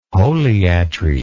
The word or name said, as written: holiatry